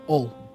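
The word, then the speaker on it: Hull: But then from my hometown, people don't really pronounce the H's. So they would say, I would say from Ull.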